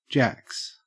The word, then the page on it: jacks: Australian English